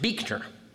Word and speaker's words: Buechner: It is pronounced Beekner.